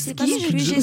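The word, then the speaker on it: Skygge: Mais écoutez Skig, ou je sais pas comment on le prononce. – Skig, Skig, Skig.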